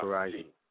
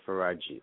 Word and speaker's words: Faraji: And the name is spelled F-A-R-A, like Farah, F-G-J-I. And it's pronounced Faraji. Yeah, Faraji. F-A-R-A-J-I. Faraji.